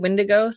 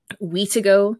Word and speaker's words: Wendigo: the spelling and pronunciation of the word Wendigo, so W-I-N-D-I-G-O, differs. So it can be Wendigo, the way I've been saying it, because that's the way I learned it. Weetigo, Windikook, Winsigo, Witigo, and Witiga, and there are all alternative versions of the same term.